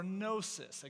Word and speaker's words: gnosis: gnosis. Again, the G is silent, but gnosis is the Greek word for knowledge.